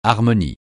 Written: harmonie

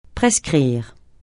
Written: prescrire

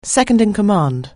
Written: second-in-command

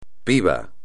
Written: pibe

